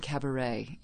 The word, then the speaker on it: Kabarette: Floating Cabaret, even though it's spelled K-A-B-A-R-E-T-T-E, in Brooklyn in the Dumbo neighborhood every week.